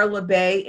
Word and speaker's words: Labbe: LeBay Insurance Agency.